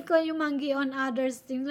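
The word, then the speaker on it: kayumanggi: We say kayumangi, but locals don't say it that much. Just brown is okay. Kayumangi. Let me see. Kayumangi.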